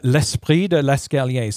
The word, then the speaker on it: L'esprit de l'escalier: L'esprit de l'escalier.